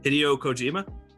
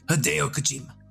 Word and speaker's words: Hideo Kojima: Hey, say his name one more time. – Hideo Kojima. – Hideo Kojima. Thank you.